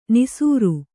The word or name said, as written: ನಾಸೀರ